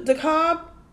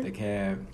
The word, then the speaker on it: DeKalb: Donald was taken to DeKalb. Did I say it right? – DeKalb. – Damn. What the fuck? That is not DeKalb.